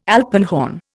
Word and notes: alpenhorn: Indian

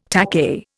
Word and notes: takahe: Indian

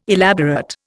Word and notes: elaborate: Indian

